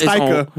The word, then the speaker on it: Taika: Taiki Waititi or whatever the fuck is that bro is Taika.